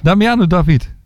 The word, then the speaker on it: Damiano David: Damiano David. – Ja, krijg ik het uitgesproken? – Damiano David. – Ja.